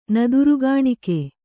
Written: ನದರುಗಾಣಿಕೆ

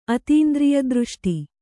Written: ಅತೀಂದ್ರಿಯದೃಷ್ಟಿ

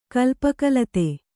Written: ಕಲ್ಪಕಲತೆ

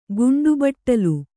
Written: ಗಂಟೆಬಟ್ಟಲು

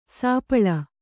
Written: ಸಪ್ಪಳು

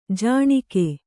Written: ಜುಣುಗು

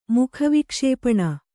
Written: ಮುಖವಿಕ್ಷೇಪಣ

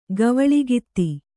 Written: ಗವಳಗಿತ್ತಿ

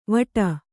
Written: ವಡ

